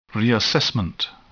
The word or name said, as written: reassessment